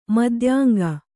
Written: ಮದ್ಯಾಂಗ

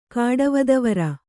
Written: ಕಾಡವದವರ

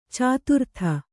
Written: ಚತುರ್ಥಿ

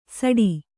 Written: ಸಾಟಿ

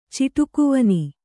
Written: ಚಿಟುಕುವನಿ

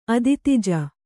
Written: ಅದಿತಿಜ

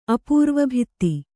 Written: ಅಪೂರ್ವಭಿತ್ತಿ

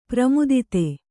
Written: ಪ್ರಮೋದೂತ

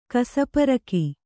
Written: ಕಸಪೊರಕೆ

